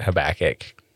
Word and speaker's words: Habakkuk: I've always pronounced it Habakkuk correctly. – Most Hebrew people have.